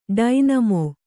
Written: ಡೈನಾಮೊ

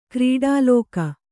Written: ಕ್ರೀಡಾಲೋಕ

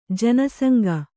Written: ಜನಸಂಗ

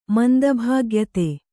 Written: ಮಂದಭಾಗ್ಯತೆ

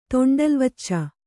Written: ತೊಂಡಲ್ವಚ್ಚ

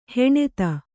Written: ಹಣತಿ

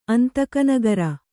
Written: ಅಂತಕನಗರಿ

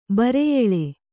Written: ಬರಿಯೆಲ್

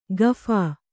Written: ಗಫಾ

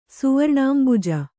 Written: ಸುವರ್ಣಾಂಬುಜ

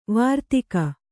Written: ವಾರ್ತಾಕ

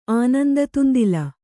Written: ಆನಂದತುಂದಿಲ

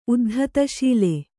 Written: ಉದ್ಧತಶೀಲ

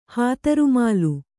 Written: ಹಾತರುಮಾಲು

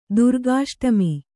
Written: ದುರ್ಗಾಷ್ಟಮಿ

